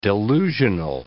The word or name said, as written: delusional